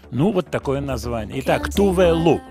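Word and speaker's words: Tove Lo: Следующая артистка – Тувелу, шведка. Тувелу, пишется Товело. Кстати, по-шведски ло, лу читается. Тувелу.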